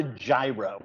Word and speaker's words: gyro: But here, it's not a gyro. That is what it is not.